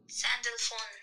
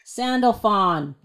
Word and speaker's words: Sandalphon: How do you pronounce it? Ready? Wait, do it. – Sandalphon. – Sandalphon. That's how you say it.